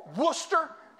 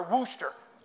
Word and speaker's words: Worcester: Worcester? I renewed my CCW. Am I saying that right? Is it Worcester or Wooster? Depends on who you ask.